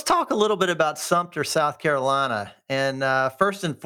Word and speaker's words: Sumter: Well, it's Sumter, but there's no P in it. People think it's Sumpter. You know, they're like, I'm like, well, it is Sumter, but it's Sumter. So Sumter.